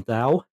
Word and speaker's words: Dow: So yeah, folks at home, this this is uh, Brian and Mitch from Dow, um, which I always pronounce the D D O W like the Dow Jones because I'm I,